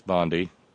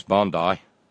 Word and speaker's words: Bondi: Bondi, which I pronounce Bondi, isn't. It is Bondi.